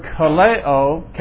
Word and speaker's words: kaleo: Klesis is spelled K-L-E-S-I-S. And it comes from the root word kaleo, K-A-L-E-O, and it means to call.